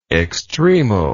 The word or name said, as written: extremal